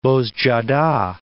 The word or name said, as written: Bozcaada